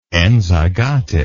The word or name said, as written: enzygosity